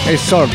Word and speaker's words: Exord: Y después la presentación de esta banda a recomendar, llamada Exord, con D final y X, Exord.